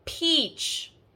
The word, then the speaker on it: peach: The first word we have has that long E sound. We say peach, and peach rhymes with beach.